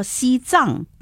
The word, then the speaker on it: 西藏: 嗯，我们说西藏它就要读藏了，